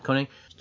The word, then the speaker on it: Koenig: Walter Koenig, I think is how you say it? – Yeah, Koenig.